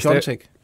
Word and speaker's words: Piontek: Og det er, ud, det er jo Pjatek, ja. men øh, udtales Piontek.